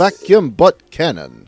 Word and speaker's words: vacuum butt cannon: It tends to, for example, in episode 3, she's supposed to be more serious in describing how you're supposed to do the vacuum butt cannon, I believe I'm pronouncing that correctly. – Yes, yes. The vacuum butt cannon.